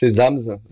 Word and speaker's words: ZamZ: Hein oui, c'est Zams, Zams avec deux Z, un, hein, au début, un hein, à la fin.